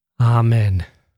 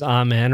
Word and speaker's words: amen: You can probably read that last word as amen or amen. It's up to you. I myself pronounce that word both ways, pretty much equally, 50% of the time. Amen, amen. It always sounds right, no matter which one you choose.